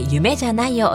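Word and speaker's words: 夢？: 大谷翔平選手の登場曲、ハテナで夢じゃないをお届けしました。この曲、夢クエスチョンマークと書いて夢じゃないと読むそうで面白いタイトルですよね。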